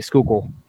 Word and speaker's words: Schuylkill: Schuylkill, the Schuylkill River.